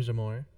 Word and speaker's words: Jahmoure: My name is like Jamor. – We are live. – Okay, welcome to Taboo Timeouts with Jay, Zai, and Chester. – I'm Zai. – I'm Jamor.